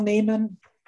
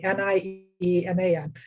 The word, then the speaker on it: Nieman: Um, pretty much, I'm under Val Nieman um, and my last name is spelled oddly, N I E M A N.